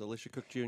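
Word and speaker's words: Elisha Cook Jr.: Alicia Cook Jr. Am I pronouncing his name? – Yes, Alicia Cook Jr. Alicia Cook Jr. is wonderful.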